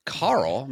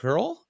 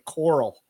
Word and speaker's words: Carl: Karl is a no-nonsense title with a tough spirit, courtesy of its roots in the Old English C-E-O-R-L. Karl? – Coral, indicating like, Rick Grimes used to say in The Walking Dead, how he pronounced his son's Carl's name all the time. There was a million fucking memes about that. – Oh, really? – Yeah, it almost sounded like he was saying Coral.